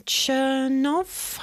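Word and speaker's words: Chernow: So it's based on the book of Hamilton's life by Ron Chernoff, I think is how you say his name, which is like 800 pages long.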